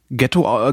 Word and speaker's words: Ghetto: Und wird halt, obwohl es ohne GH geschrieben wird, also nur G-E-T-T-O, äh, wird es trotzdem ähm, Ghetto, äh, Ghetto ausgesprochen, weil nämlich die äh, Gründer des jüdischen Viertels in Venedig Einwanderer, jüdische Einwanderer aus Deutschland waren.